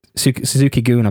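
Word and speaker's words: Suzuki-gun: Suzuki Goon, I believe it's pronounced properly.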